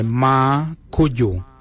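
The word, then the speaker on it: marrgoodjal: Ma ken is spelled M-A-R-R-G-E-N-Y. Ma ken. For the number 7 we say ma kuju. Ma kuju is spelled M-A-R-R, then it's G-O-O-D-J-A-L.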